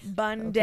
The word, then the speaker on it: Bundy: Bundy. Okay. It's spelt like Sunday, but with a B.